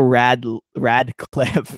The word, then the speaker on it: Radcliffe: Radcliffe. – I always pronounce his last name wrong. I always want to say Rat. – Cliff. – Instead of Rad. So it's Daniel Radcliffe.